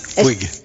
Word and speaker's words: Puig: No, es que Puig en catalán, sí, el Puig es Puig. Se pronuncia así.